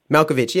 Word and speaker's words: Malkovich: Malkovich, yeah. Thank you for correcting Rolls-Royce's pronunciation there. It is Malkovich. You can call him Malkovich all day if you like.